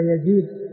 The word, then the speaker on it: يزيد: يعني هذا يزيد بفتح الياء وهذا بريد بضم الباء, فالرسم واحد, وهذا اللي يسمونه المؤتلف والمختلف, يسمى في علم الصلاح المؤتلف والمختلف, بأن يتفق الرسم والشكل ويختلف النطق ويختلف النطق.